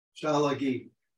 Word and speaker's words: Tsalagi: Chalagi. – Chalagi. – It's spelled T-S-A-L-A-G-I.